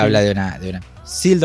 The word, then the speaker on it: Seal: O sea, se pronuncia SEAL, pero es SEAL, que es foca, sería.